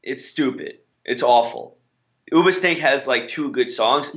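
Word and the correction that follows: Hoobastank: You're not even pronouncing their name right. – Ubastank. – Huba? – Huba. – Yeah, it's not Uba.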